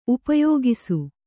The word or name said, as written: ಉಪಯೋಗಿಸು